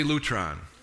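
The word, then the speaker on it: lutron (λουτρόν): Easy to pronounce. Say lutron.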